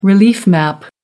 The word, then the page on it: relief map: American English